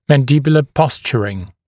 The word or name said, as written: mandibular posturing